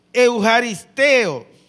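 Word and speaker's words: eucharisteo (εὐχαριστέω): En griego la palabra se escribe euxapiotew y se pronuncia eucharisteo, que significa ser agradecido activamente y expresar gracias.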